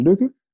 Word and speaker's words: Lykke: And, you know, he's pronouncing so, you know, very good the Danish words, Hygge and Lykke.